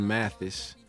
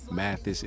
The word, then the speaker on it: Mathis: And you can find me on Facebook at Quillan Mathis. You say chilling, then you say Quillan. That's how you pronounce my name. That's how you got QB chilling. QUILLAN Mathis, MATHIS.